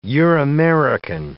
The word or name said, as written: Euramerican